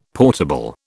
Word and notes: portable: British English

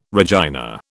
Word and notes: Regina: British English

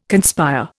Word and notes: conspire: Indian

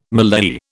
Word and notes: mallei: British English

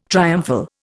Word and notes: triumphal: Indian

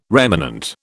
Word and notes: remanent: British English

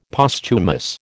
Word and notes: Posthumous: American English

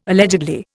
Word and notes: allegedly: Indian

